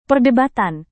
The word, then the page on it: perdebatan: Indonesian